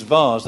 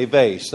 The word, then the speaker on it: vase: English pronunciation is vase. That's the correct one. The Americans say vase. That's the wrong one.